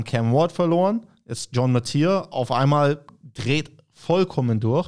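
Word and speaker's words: Mateer: Spricht man ihn so aus? – Ja. Yep. – Oder? Hätte ich es auch gesagt. Metzier.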